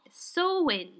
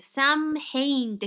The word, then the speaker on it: Samhain: ソー i ンっていう発音なんですけど、まあ、綴りは SAMHAIN って書いて、サムヘインって書いて、ソー i ンって発音なんですけど、えー、このソー i ンっていうのは、えー、古いアイルランド語の so まあサメインって書いてソウインかな。